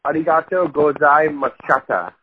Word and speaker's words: arigato gozaimashita: Okay, it is arigato gozaimashita. That's how you say it.